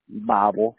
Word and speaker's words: Bible: E-T-H-A-N? – Yes. – And then what's your last name, Ethan? – Bible, B-I-B-L-E.